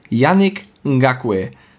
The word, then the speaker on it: Yannick Ngakoue: Yannick Ngakoue. – Erstmal ein Applaus dafür, dass du den Namen so hingekriegt hast. – Ich hoffe, das spricht man so aus. Ja, das war genau richtig.